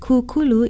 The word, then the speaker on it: kukulu: Kukulu is spelled K-U-K-U-L-U with a makron or kahako over the first vowel. Say kukulu. You can also use kukulu to mean set up, such as a tent, or to found, such as a society.